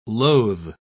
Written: loathe

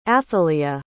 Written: athaleyah

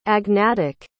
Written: agnatic

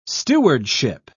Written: stewardship

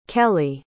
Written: kelli

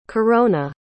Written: carona

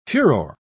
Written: furor

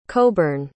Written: coburn

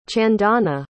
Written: chandana

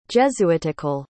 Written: jesuitical

